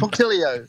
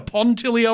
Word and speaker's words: punctilio: Punctilio. – Hang on, wait a minute. Say it again. A pontilio or a punctilio? – Punctilio. And punk.